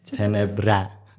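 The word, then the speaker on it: tenebrae: ریشه لاتینش تنبره اینجوری تلفظ میشه.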